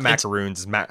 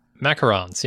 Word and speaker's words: macaron: It's not macaroons. – Macarons, yeah.